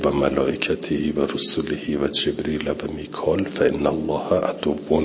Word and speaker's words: جبریل: قرأ ابن کثیر، ابن کثیر خونده من کان عدوا لجبریل ریل به فتح الجیم و کسر را من غیر همزه جبریل.